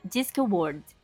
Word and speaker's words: Discworld: E também é um dia glorioso pros fãs de Discworld.